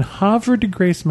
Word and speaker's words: Havre de Grace: and they have the Havre de Grace Decoy Museum.